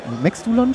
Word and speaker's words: Maxdulan: So, wie sind die Köpfe hinter Maxdulan? Maxdulan? Spreche ich das richtig aus? Ja, das ist richtig ausgesprochen. Genau.